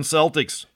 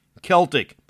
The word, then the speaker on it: Celtic: It should be strongly noted that in Gaelic, the native language of Ireland, and the Celts, there is no soft C sound. It should be a hard K, Celtic. Only in basketball is it Celtic.